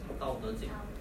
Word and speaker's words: Tao Te Ching: Tao Te Ching.